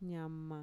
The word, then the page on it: ñama: Mixtec